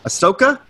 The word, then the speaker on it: Ahsoka: You told me how to say this name, Ahsoka? – Yeah, you got it.